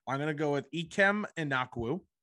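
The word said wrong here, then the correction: Ekwonu: I probably butchered his name. – But Ikwonu, I think it is. – Ikwonu.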